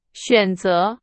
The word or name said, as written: 选择